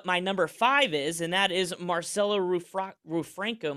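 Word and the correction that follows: Rufrano: Am I saying that correctly? – Oh, God. Marcello Ruffrano. – Ruffrano. Yeah. Well, you know what? A for effort.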